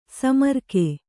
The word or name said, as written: ಸೋಮರಾಗ